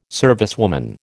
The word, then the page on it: servicewoman: American English